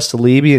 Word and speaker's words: Saleebey: Billy Samoa Salibi, and you can correct me, Billy, if I'm pronouncing your name wrong. – That's impressive. You got it, man.